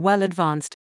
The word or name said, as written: well advanced